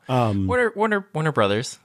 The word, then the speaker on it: Warner Bros.: Warner Brothers. We just say Warner Brothers. Nobody says Warner Bros.